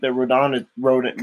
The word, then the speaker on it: Rodan: And yes, it is pronounced Rodan. – Rodan, yeah, that's what I'm saying.